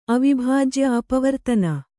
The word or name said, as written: ಅವಿಭಾಜ್ಯ ಅಪವರ್ತನ